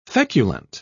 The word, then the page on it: feculent: British English